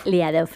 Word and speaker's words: Liadov: Liadov,